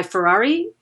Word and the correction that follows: Fieri: Is that how you say his name? Fieri. Fieri. Guy Fieri.